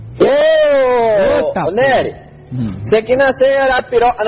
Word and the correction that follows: Arapiraca: não, Arapiraca, mano, de novo falei errado.